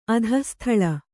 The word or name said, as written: ಅಧಃಸ್ಥಳ